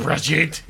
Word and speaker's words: Brigitte: And she it's pronounced Brigitte.